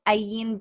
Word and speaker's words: Ayn: Yes, so you can find me at Ayn Bernos everywhere, pretty much just AYN.